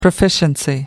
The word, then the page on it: proficiency: American English